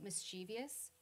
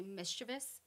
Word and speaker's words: mischievous: One of the other words that surprised me when I was learning correct pronunciations was that most people say mischievous, but it's actually mischievous.